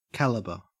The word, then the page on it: calibre: Australian English